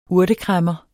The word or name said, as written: urtekræmmer